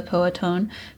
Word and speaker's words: Powhatan: He also developed relationships with Chief Powhatan. Sorry if I didn't pronounce that right. The Powhatan. Leaders. Yeah. Is that how you say it? – Mm-hmm.